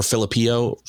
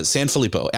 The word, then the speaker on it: Sanfilippo: Alex Sanfilippo or Filippio. – Sanfilippo. – Yeah. There you go. Yeah. Sanfilippo.